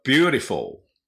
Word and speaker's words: beautiful: では、 10 番、美しいは、 beautiful。 beautiful。これは、 beautiful じゃないですね。 full、 full。